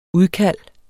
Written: UDKALDET

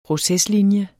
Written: proceslinje

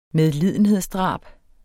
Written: medlidenhedsdrab

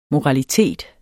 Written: moralitet

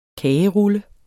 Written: kagerulle

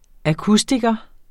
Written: akustiker

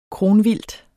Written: kronvildt

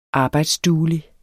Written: arbejdsduelig

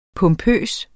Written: pompøs

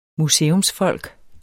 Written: museumsfolk

